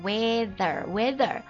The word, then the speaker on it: weather: 先说一下读音，w 过了之后是要咬舌头的，这个长得像六的这个辅音字母啊，the weather。